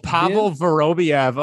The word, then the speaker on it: Pavel Vorobiev: Pavel Vorva B. – Oh, Pavel Vorobiev. Oh, he was. – Yeah, there you go. – He was this Russian. I butchered that one.